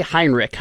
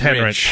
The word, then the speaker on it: Henrich: So Garrett Nelson, Nick, how do you say, I say Heinrich. How do you? – It's Henrich. – Henrich? Okay. – So it's Nick Henrich.